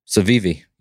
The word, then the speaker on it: Civivi: Civivi, yeah, C I V I V I.